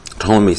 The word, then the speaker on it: Ptolemy: From the time of Hesiod until at least the deaths of Ptolemy. Ptolemy spelt P-T-O-L-E-M-Y. It took me a while to pronounce that properly.